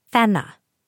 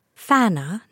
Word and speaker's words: Fenna: Fenna. Not a challenging name to pronounce. Basic English phonics. Short E sounds like eh. Short A sounds like ah. I do not understand why people hear Fenna, even with our Canadian accent, and say Fana. No, Fenna.